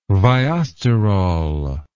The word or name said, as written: viosterol